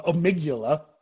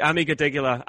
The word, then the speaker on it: amygdala: I, I, tell you, you know, you, you talk about the baseline of your um, um amygdala. I probably pronounced that wrong. Amig- amygdala.